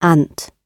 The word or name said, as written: ant